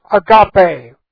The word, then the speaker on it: agape: The Greek word is agape. A-G-A-P-E long E. Agape.